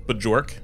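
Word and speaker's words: Björk: Excuse me, I think her name is pronounced Bjork.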